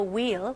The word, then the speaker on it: wheel: Rotate the wheel. 휠 아니에요. H는 묵음입니다.